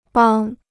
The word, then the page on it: 帮: bāng